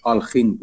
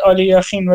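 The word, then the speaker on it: آلخین (Алехин): اونو من اونشو خبر ندارم آلیاخین نیستش الکساندر الکساندروویچ آلخین بودش سال 1946 هم فوت کرد نخی این خیلی قدیمیه آره میش... میشناسمش بازیشو آره. آره. دیدم توی کانالی نگاه میکنم طرف تلفظ میکرد آلی یخین ولی خب شاید اشتباه تلفظ میکرده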